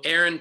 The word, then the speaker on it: Erin: The only thing is, if you're listening and didn't see you spell it in my name, it's actually E-R-I-N. And if you're thinking to yourself, aren't most E-R-I-N Aaron's women? You would be correct.